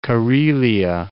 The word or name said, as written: Karelia